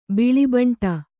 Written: ಬಿಳಿಬೆಂಡು

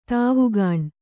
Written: ದವಾಖಾನೆ

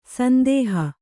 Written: ಸಂದೋಹ

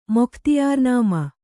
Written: ಮೊಖ್ತಿಯಾರ್‍ನಾಮ

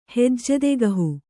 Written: ಹೆಜ್ಜೆದೆಗಹು

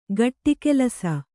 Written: ಗಟ್ಟಿಕೆಲಸ

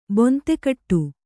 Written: ಬೊಂತೆಕಟ್ಟು

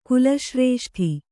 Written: ಕುಲಶ್ರೇಷ್ಠಿ

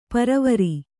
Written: ಪರವರಿ

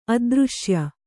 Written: ಅದೃಶ್ಯ

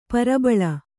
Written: ಪ್ರಬಲಿ

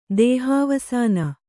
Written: ದೇಹವಾಸನೆ

